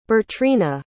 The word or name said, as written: Bertrina